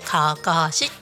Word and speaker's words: 案山子: かかしって読むんですけどそちらの方でカフェをやっております。